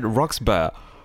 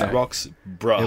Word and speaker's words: Roxburgh: And there's, I did not say the G at all. I said Roxburgh.